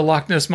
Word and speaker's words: Loch: I heard somebody talk about the Loch Ness Monster. – Okay. – But they pronounced the CH. Oh. It wasn't Loch Ness. They called it the Loch Ness Monster.